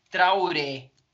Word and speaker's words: Traoré: Mano, é bem como se fala mesmo. Boubacar. Tá. Traoré.